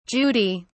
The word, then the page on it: judy: British English